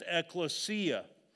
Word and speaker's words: ecclesia: Uh, but it's pronounced ecclesia.